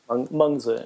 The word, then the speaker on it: Mengzi: Mengzi in Chinese.